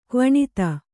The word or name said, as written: ಕ್ವಣಿತ